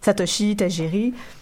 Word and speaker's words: Satoshi Tajiri: Donc, le créateur Satoshi Tajiri, pardonnez ma prononciation du japonais, parfait.